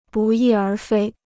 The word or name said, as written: 不翼而飞